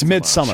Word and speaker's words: Midsommar: Did you say Somar? – Somar. – It's Midsommar.